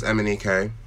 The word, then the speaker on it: Emenike: So my surname is Emenike. Mm-hmm. And it's spelled E-M-E-N-I-K-E.